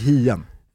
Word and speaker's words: Hien: Isak Hien. Ja, ja. jag körde italienska. – Igen. igen. – Stumt H.